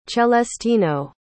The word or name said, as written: celestino